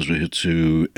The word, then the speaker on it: zuhitsu: Some of the poems in this book take the form of zuhitsu. Is that how you pronounce that? – Zuhitsu, yeah.